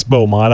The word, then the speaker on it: Beaumont: I thought it was it's, Bo. It's, it's It is. It's Beaumont. I was just calling it